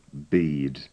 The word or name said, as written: bead